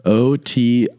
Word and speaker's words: otiosity: I don't think it really matters how you put the stress on the first O, but otiosity.